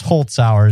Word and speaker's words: Holzhauer: So right now, James Holzhauer is his name, is averaging more than $77,000 per show.